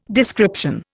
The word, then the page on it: description: Indian